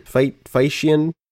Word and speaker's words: Feitian: I can't pronounce that. Feitian? F-E-I-T-I-A-N. Feitian? – Yeah, Feitian.